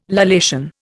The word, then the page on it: lallation: Indian